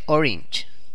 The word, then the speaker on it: Orange: Que no se dice Orange, se dice Orange.